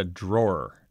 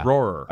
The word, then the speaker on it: drawer: I call it a draw. It's like you're drawing a picture. That's how I say the thing. – Drawer. – A drawer. It doesn't come out right to me.